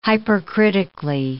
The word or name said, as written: hypercritically